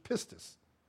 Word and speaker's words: pistis: The Greek word for faith in the Bible is the word, this is, as far as I understand, the actual Greek pronunciation, pistis. P-I-S-T-I-S. In the English, it's spelled pistis, P-E-S-T-S.